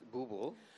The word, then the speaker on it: Booble: I know it's called Bubble, yes. – And it's got a very unique source of inspiration behind it. – Well, it's actually pronounced Booble. – Ooh, Booble. My bad, Booble, sorry.